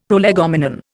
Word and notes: prolegomenon: Indian

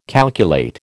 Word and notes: calculate: American English

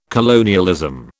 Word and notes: colonialism: British English

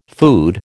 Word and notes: food: American English

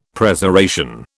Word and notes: Preservation: British English

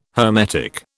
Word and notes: hermetic: British English

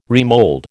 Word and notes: remold: American English

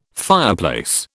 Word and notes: fireplace: British English

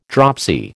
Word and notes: dropsy: American English

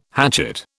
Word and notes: hatchet: British English

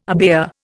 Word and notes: abaya: Indian

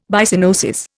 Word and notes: Byssinosis: Indian

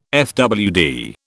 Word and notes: fwd: British English

